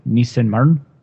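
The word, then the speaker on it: Nissemann: My number four is called Hard to Be a Nisseman, or actually they pronounce it Nissenmarn, but I can't really say it correctly.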